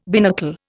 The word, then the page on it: binnacle: Indian